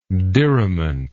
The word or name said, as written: diriment